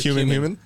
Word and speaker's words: cumin: Human rhymes with cumin.